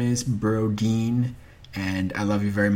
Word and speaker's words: Brodin: Their name is Brodeen, not Broden. Even though, in my defense, it is spelled B R O D I N without the E. So it, I, I, I pronounced it like it's spelled.